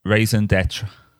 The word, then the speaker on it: raison d'être: Raison d'être.